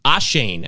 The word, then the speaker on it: Achane: Obviously, Devon Achane. Achane, actually, is how you say it.